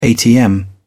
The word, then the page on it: atm: British English